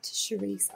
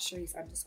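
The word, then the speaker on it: Shereece: It's at Charisse _ Mark. And I'll spell it because it's a bit of an arsehole name. Mm-hmm. So it's S-H-E-R-E-E-C-E _M-A-R-C. So at Charisse _ Mark.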